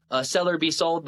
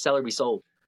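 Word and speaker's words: Sell or Be Sold: And it's funny, he doesn't pronounce it D's. He says Seller Be Sold. And so that's anytime I say the name of the book, you got to say it like he says it, because he says Seller Be Sold. Like he says the whole line.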